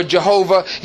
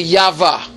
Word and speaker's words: Yahweh: But a more accurate name is actually Yahweh. Yahweh is the same thing as Jehovah or Yahweh. So if you hear me refer to God as Yahweh, that's who I'm talking about. I'm talking about Yahweh. I'm talking about Jehovah. But it's more closely pronounced Yahweh, you know, when it's pronounced.